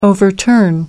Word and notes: overturn: American English

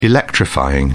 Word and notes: electrifying: British English

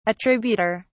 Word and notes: attributor: British English

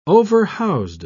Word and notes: overhoused: British English

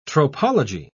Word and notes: tropology: British English

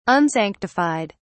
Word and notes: unsanctified: British English

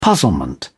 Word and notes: puzzlement: British English

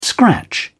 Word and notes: scratch: British English

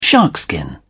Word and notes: sharkskin: British English

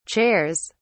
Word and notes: chairs: British English

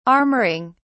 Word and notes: armouring: British English

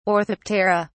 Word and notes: orthoptera: British English

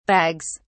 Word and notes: bags: British English

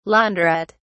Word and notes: laundrette: British English

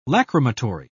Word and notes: lachrymatory: British English

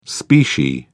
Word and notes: specie: British English

